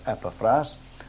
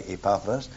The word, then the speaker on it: Epaphras: Depends where you come from as to how you pronounce that word. Some people say Epaphras, other I say Epaphras.